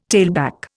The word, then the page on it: tailback: Indian